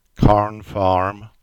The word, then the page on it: Carn Farm: English